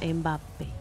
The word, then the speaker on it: Mbappé: Todavía no le vi a Mbappé, que parece tampoco que sí, mi sobrino tenía razón y se pronuncia Mbappé.